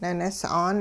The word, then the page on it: ninis'aan: Hupa